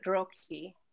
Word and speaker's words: Roqui: And so I just chose the word Roqui since it rhymes with coqui.